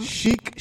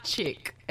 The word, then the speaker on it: chic: But So because of slang, I get a lot of chick. It's chic. C-H-I-C. It's pronounced S-H-E-E-K. Chic.